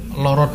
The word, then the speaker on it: lara: Jadi apa namanya itu termasuk ke dalam istilahnya variasi dalam bunyi huruf A, tetapi pengucapannya O. Dan kita pun mengejanya pun menuliskannya itu L-A-R-A, Lara gitu. – Karena kalau kita nulis loro dengan L-O-R-O itu nanti apa bedanya dengan bahasa Jawanya huruf angka 2? – Loro juga ya.